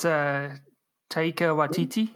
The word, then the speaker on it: Taika Waititi: Yeah, isn't that I think that's Taika Waititi.